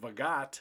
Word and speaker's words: Vogt: Now, it's spelled V-O-G-T. It looks like Vogt. – Yeah, it's spelled really hard to pronounce. – It's pronounced Vogt, which I think is kind of ironic that we have a new manager called Vogt in an election year.